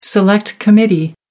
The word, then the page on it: select committee: American English